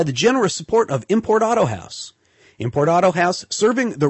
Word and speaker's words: Autohaus: Auto House is spelled AUTOHAUS, the German way. Import Auto House.